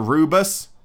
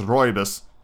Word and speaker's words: rooibos: Or rubus, or rooibos, however you want to pronounce it. It is correctly pronounced rooibos. R-O-O-I-B-U-S.